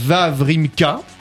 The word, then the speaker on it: Wawrinka: C'est Stan Wawrinka. – Oui, si je prononce bien. – Très bien, très bien prononcé.